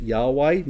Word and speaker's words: Yahweh: His name Jehovah, or you might pronounce it Yahweh, means this very thing.